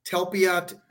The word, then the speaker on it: Talpiot: T-A-L-P-I-O-T. Telpiot tomb.